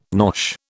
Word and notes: nosh: British English

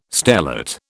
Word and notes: stellate: British English

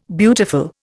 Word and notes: beautiful: Indian

